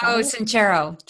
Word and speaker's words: Sincero: Sincero. – Sincero.